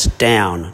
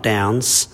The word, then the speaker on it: Down: There's no S. It's down, not downs.